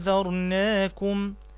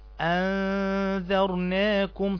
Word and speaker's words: أنذرناكم: إنا أنذرناكم، أنذرناكم، عندنا حرف الذال، وحرف الذال من الحروف الإيه؟ اللثوية اللي هي حروف إخراج اللسان، فتنطق هكذا: أنذرناكم أنذرناكم، تخرج لسانك في حرف الذال، أنذرناكم عذابا أنذرناكم عذابا.